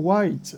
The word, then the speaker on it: Whyte: My name is Michael White. The White is spelled W-H-Y-T-E.